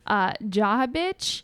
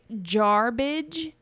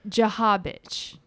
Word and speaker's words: Jarbidge: And the way it's spelled is J-A-R-B-I-D-G-E. So it looks like jarbage, but it said Jahabich.